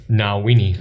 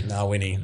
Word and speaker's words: Ngawyni: Ngawyni. – Ngawyni.